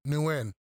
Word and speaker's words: Nguyen: Nguyen.